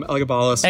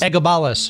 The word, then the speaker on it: Elagabalus: Yeah, I think people pronounce it variously. Um, Elagabalus. Elagabalus. Yeah, uh, people say it um, quite differently, and especially between British and American pronunciations.